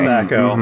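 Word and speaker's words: THAC0: It is pronounced Thaco.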